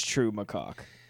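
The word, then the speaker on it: Trou Macacq: How do you feel about True Macaque? – Is that how you say it? I heard him sing it, but I thought that might have just been... – I think it's True Macaque. T-R-O-U.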